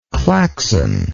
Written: claxon

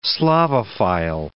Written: Slavophile

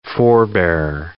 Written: forebearer

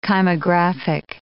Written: kymographic

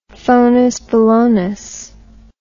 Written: phonusbolonus

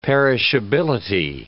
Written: perishability

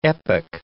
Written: epoch

